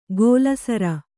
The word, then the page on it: ಗೋಲಸರ: gōla sara